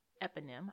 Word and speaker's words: eponym: Let me see if I'm pronouncing it right. You guys can't hear it today. It's so low. Eponym. I did say it right.